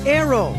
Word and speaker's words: Naoero: This program is previously called the country Nauru, N-A-U-R-U. The 11,000 residents have altered Nauru to be now Aero, N-A-O-E-R-O. They're going back to the pronunciation in their native language and losing a pronunciation used by outsiders.